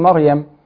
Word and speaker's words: مريم: فأنا عندي الميم مفتوحة، ما الراء ساكنة مر، واخدة الحركة بالتفخيم. – تمام. – عشان الميم المفتوحة، والياء مفتوحة مر ياء. – تمام. – فإذا تكون مريم. – تمام. – ده النطق الصحيح بتاعها.